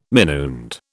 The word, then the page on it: minuend: British English